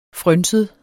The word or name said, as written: frynset